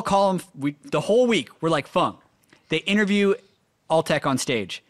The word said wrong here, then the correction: Fang: His teammates call him Fang it's spelt Fang.